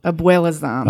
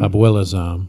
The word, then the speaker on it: Abuelazam: Abuelazam. – Abuelazam. – Yes. – And he's an Israeli Arab, but I can pronounce his last name well because it's Abuela, which is grandma. – Spanish for grandma.